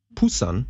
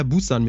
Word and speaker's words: Busan: Wahrscheinlich, weil sie das B aussprechen wie ein P. Also die, die Airline heißt Air Busan mit einem B.